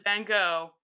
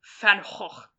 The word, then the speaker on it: Van Gogh: I absolutely believe that you can and should pronounce it Van Gogh, but I find it extremely funny that it is, in fact, according to the Dutch, pronounced Van Gogh.